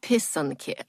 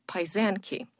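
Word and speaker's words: pysanky: Pisanki. I know somewhere along the line, someone pronounced it as paisanki. And unfortunately, some people know them as that word, but that is just not correct.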